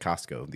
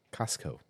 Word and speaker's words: Costco: I bought one at Costco the other day, actually. – The Costco. – The Costco. Or in Utah, Costco. – Yeah, Costco. There is Costco. No T in Costco. It's just COSCO. Costco.